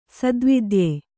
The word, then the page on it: ಸದ್ವೇದ್ಯ: Kannada